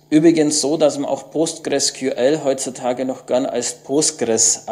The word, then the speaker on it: PostgreSQL: Wer wissen will, wie man das ausspricht, es gibt auf der Webseite eine hübsche MP3-Datei. – PostgreSQL. – Also so spricht man es offiziell aus. Das ist von einem der Postgres-Erfinder, diese Aussprache.